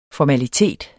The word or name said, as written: formalitet